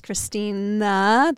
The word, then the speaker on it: Christina: As soon as you add another syllable, it gets that much more difficult, and so I'll get called Christine. Mm. Like, it's Christina, that last syllable, like, it gets you.